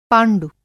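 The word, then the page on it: पांडु: pāṇḍu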